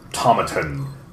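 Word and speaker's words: Tomatin: Established in 1897, Tomaton, to rhyme with satin.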